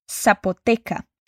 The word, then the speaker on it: Zapoteca: Zapoteca. Agora, vamos acrescentar a comida que eles querem pedir. E muito cuidado, porque apesar de ser escrito com Z, a palavra zapoteca é pronunciada com som de S, tá?